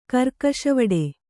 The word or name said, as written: ಕರ್ಕಶವಡೆ